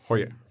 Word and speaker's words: Heuer: Nu vill jag ha en Tag Heuer eller vad den kan uttalas. – Hoje.